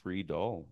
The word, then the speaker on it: Friedl: Yeah, no, it's Friedel.